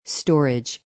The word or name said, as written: strage